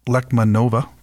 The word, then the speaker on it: Lechmanova: And her name is Lenka Lekmanova. Did I pronounce that last name correctly? – Yes, you did.